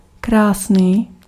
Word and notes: beau: /bo/; France

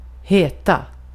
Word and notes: heta: /heːta/

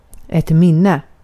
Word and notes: anı: turkiska

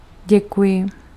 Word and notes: merci: Paris; [mɛʁ.si]; /mɛʁ.si/

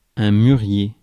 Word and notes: mulberry: American English; [ˈmʌl.ˌbɛɹ.i]; /ˈmʌlbeɹi/

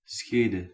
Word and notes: schede: /ˈsxeː.də/

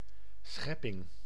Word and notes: schepping: /ˈsxɛ.pɪŋ/

